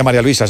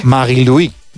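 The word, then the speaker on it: Marie-Louise: No se dice María Luisa, se dice en su... – Sí. – Marie-Louis.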